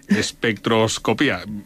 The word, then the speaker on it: espectroscopía: Porque hay que poner el acento en la I. – En la I. – Espectroscopía.